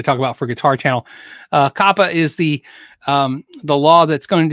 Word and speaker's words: COPPA: I think it's COPPA is how they say it.